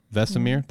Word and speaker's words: Vesemir: Vesemir? Did I nail it? – Vesemir.